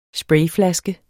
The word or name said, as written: sprayflaske